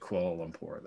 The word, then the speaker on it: Kuala Lumpur: It looks like it's koala, but it's spelled differently than the koala from Australia. – From what I understand, you do pronounce it Kuala Lumpur, though, so you got that.